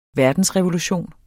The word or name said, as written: verdensrevolution